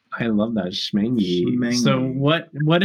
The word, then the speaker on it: Schmengie: Schmangy. – Correct. – Schmangy. – Schmangy. It's spelled S C H M E N G I E. It has a C in it.